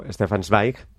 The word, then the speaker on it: Stefan Zweig: Seguimos con Tolstoy porque escribe el autor austriaco Stefan Zweig, ¿lo digo bien? Sí. Bien.